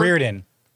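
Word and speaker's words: Riordan: Roydan, whatever. – Oh, Reardon.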